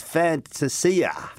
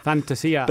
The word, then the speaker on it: Fantasia: Where are we at with the pronunciation of Orazio's surname? – I can tell you where we're at. No, I know where we're at. He is, I think, in Italia... It is Fantasia.